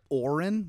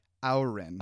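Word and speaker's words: Auryn: I always thought it was like Auron with an O, yeah. – But it's spelled A-U-Y-R-N. So it's like Auron.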